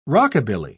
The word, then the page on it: rockabilly: British English